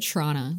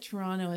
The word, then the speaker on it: Toronto: I don't usually pronounce the second T. – It's so. Okay.